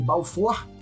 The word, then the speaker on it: Balfour: Se escreve Balfour, mas as fontes aqui afirmam que é Balfour o nome dele, porque o nome dele era Arthur Balfour, tá?